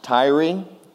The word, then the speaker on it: Tyre: I believe it's pronounced Tyre, T-Y-R-E, and this is a little backstory on this king of Tyre.